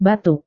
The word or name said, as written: batuk